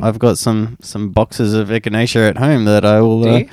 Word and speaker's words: echinacea: You can't pronounce echinacea? – Is it echinacea? Yeah. Echinacea.